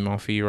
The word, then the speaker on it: Monfils: I think Monfils.